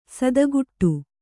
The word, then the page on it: ಸಾತಗಡಿ: sātagaḍi